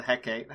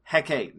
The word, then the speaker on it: Hecate: So the Empress appears around a bit of the Hecate, or the Hecate, however you want to pronounce it. Hecate, new from Netflix.